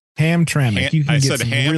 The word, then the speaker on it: Hamtramck: Hamtramck.